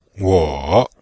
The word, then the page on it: wo: wo3